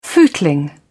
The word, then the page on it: footling: British English